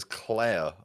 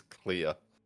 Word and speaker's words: Clear: Pronounces it as Claire a couple of times. – Yeah. – But it's spelled and written and said by Alex and maybe other characters, it's pronounced as Clear. C-L-E-A-R.